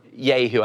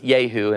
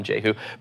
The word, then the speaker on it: Jehu: or Yehu, I guess, is actually how it's pronounced because it's J and it's the silent J. So it's Yehu, and it's kind of weird to say that, so I'm going to call him Jehu.